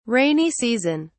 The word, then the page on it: rainy season: British English